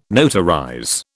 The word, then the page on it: notarize: British English